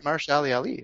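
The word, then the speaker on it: Mahershala Ali: it's Marshall Ali. – I think that's, exactly right.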